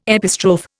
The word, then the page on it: epistrophe: Indian